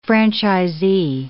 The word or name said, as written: franchisee